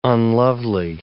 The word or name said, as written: unlovely